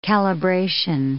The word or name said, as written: calibration